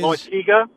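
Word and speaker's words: Loáisiga: You have AJ Cole here, you have that kid in the minor leagues, and help me with the pronunciation, Loisiga, I think it is. – Loisiga. – Loisiga.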